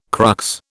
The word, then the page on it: crux: British English